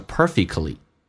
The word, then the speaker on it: perfectly: Doing absolutely nothing yet feeling perfectly happy and content，而且会感到 perfectly，这个单词 T 不要发音，你要记住啊，在美式发音当中三个辅音排列中间是不用发的。你看 perfectly 是不是有三个辅音，那个 t 就不发音，发成 perfectly，